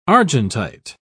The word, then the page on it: argentite: British English